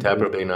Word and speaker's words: Taprobane: Taprobane, is that how you say it? – I would say it that way. – And Taprobane Island.